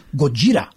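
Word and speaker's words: Gojira (ゴジラ): And of course, the Japanese pronunciation is Gojira.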